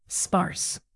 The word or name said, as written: sparse